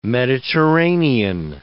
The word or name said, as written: Mediterranean